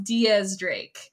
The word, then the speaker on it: X Drake: Apparently it's pronounced Diaz Drake. You wouldn't know that from just reading the manga.